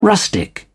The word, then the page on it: rustic: British English